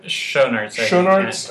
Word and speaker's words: Schoenaerts: She's nursing her voice in this villa on an Italian island with her lover of 6 years, Paul, played by Matthias Schoenaerts. Is that how you say it? – Schoenaerts? Yeah.